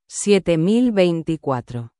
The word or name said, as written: 7024